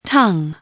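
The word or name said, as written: tongue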